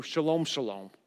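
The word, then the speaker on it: shalom shalom: Perfect peace, this is going to blow your mind, is actually pronounced in the Hebrew, shalom, shalom.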